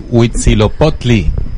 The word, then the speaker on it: Huitzilopochtli: Bien, entonces, Huitzilopochtli.